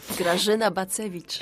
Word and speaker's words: Grażyna Bacewicz: Grażyna Bacewicz.